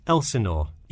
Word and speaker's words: Elsinore: I'm sorry, was that Eleanor? Could you spell that for me? – Sure. It's Elsinore. E-L-S-I-N-O-R-E.